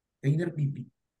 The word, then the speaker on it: Tanner Bibee: Y hay uno de Cleveland que está como, está, bien, no sé si lo han visto pinchar, Tainer Bibi que se pronuncia así, Tainer Bibi Tainer.